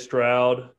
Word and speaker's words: Stroud: I think CJ Stroud, I say his name correctly.